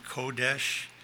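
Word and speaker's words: kodesh (קודש): Strong's dictionary defines the Hebrew word for holy as, I just pronounce it, kodesh.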